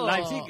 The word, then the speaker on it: Leipzig: Leipzig es lo que nos dijeron que que teníamos que decir.